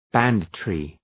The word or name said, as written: banditry